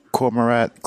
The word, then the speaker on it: Cormorant: So how does that relate to the Cormorant Clean Energy Project? I hope I pronounced that word correctly. – Yeah, that was exactly right.